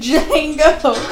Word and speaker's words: Django: The Django. – Said the D is silent hillbilly and shot him.